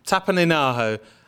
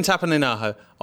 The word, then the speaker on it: Tapaninaho: And we've also been directed to pictures of listeners Christian, how do I say this, Tapananio? – I'm glad you read that, I'm afraid, Bobby. – Tapaninaho. Christian Tapaninaho on the Flickr Guardian Tech Weekly group, and Daniel Pacey on the I Love the Guardians Tech Weekly podcast Facebook group.